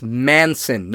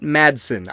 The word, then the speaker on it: Manson: And yes, that's Manson, not Madsen. I keep pronouncing it Madsen. It's Mark Manson, like the serial killer.